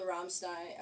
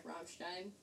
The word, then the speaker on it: Rammstein: Of course, I know the Rammstein. Rammstein. – Yeah. – I don't know how to say it very well. – Stein is appropriate.